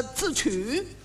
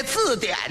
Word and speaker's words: 字典: 我说呀，你们也不信，你们不要忙，来，说着话一翻这本书，来，我给你们查查这个字曲。他也错了一个，这字典呢，他给念成了字曲了，合着把底下两点给取消了。